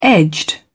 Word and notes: edged: British English

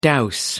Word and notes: dowse: American English

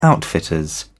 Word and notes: outfitters: British English

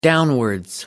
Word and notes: downwards: American English

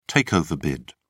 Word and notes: takeover bid: British English

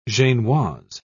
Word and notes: genoise: British English

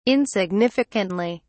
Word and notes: insignificantly: British English

